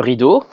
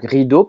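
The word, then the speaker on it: ridå: Donc rideau, par exemple. En français, ça s'écrit RIDEAU, alors qu'en suédois, ça s'écrit RID et A avec un petit rond par-dessus qui se prononce O.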